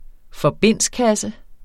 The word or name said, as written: forbindskasse